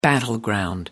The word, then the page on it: battleground: American English